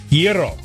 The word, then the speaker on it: gyro: G-Y-R-O. – And Giro. – Hold on. Hey, wait 1 second. The G is silent. Giro. – Rhymes with zero.